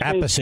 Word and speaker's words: Appice: We always said a piece, but he pronounced his name a different way, his last Appice. – Name. – Appice, yeah.